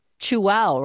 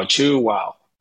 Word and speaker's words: Chuao: Welcome with Chew-Wow, right? That's how you pronounce it. Perfectly pronounced, yes. You chew and say wow, Chew-Wow. – And it's spelled C-H-U-A-O.